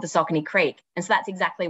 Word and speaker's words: Saucony: Fun fact is it is actually pronounced Saucony, so Saucony.